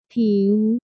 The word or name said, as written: ผิว